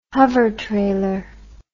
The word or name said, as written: hovertrailer